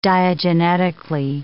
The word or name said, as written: diagenetically